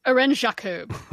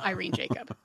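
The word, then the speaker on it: Irène: No, it's Irene. She's French. Irene Jacob, not Irene Jacob.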